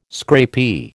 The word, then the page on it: scrapie: American English